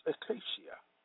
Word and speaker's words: ecclesia: The Greek word for church is ecclesia, pronounced ecclesia, meaning one, an assembly of Christians gathered for worship in a religious meeting.